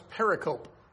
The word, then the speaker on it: pericope: It's spelled P-E-R-I-C-O-P-E. You would think it's pronounced pericope. But actually, the correct pronunciation is pericope.